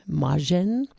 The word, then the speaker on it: Majcen: New music from Leon Moschen. I think that's how you pronounce that last name. I'll have to ask Chris Holland. How would you pronounce M-A-J-C-E-N? Moschen? That sounds good.